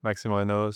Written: Maxime Raynaud

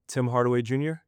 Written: Tim Hardaway Jr

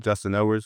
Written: Justin Edwards

